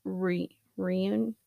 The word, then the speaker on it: Runyon: One such case was the kidnapping and murder of Samantha Runyon. It's like pronounced like onion with an R, or not pronounced, it's spelled. Anyway, which prompted California to establish an Amber Alert system in July of 2002.